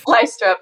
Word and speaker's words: Lystrup: Lystrup. It's kind of like strip.